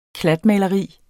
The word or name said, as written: klatmaleri